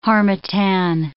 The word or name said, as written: harmattan